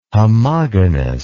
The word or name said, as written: homogonous